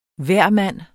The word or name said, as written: hvermand